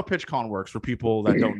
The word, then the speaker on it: PitchCom: Is that how you say it? PitchCom? PitchCom? – PitchCom, PitchCom. – Yeah, PitchCom.